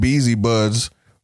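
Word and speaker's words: Beazy Budz: At Beezy Buds on pretty much everything.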